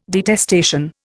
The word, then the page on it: detestation: Indian